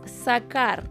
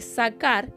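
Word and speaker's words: sacar (זכר): En el original, cuando dice varón, la verdadera palabra que se utiliza, voy a tratar de pronunciarla, es sacar. Se escribe Z-A-K-A-R, sacar.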